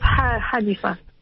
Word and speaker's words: Hanifa: It's a soft sound, it's just Hanifa.